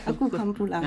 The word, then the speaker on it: Aku Kan Pulang: Zit er Aku Khan Pulang? – Ja, Aku Khan Pulang. – Ja, goed uitgesproken. Ja.